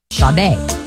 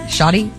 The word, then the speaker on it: Sade: Sade. – This is sexy time music. – What did I say? Shoddy? – Sade. – Oh, it's spelled like that. That's not even fair.